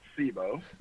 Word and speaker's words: SIBO: SIBO or SIBO? – Typically it's SIBO. – SIBO. I've been the one saying it wrong all this time.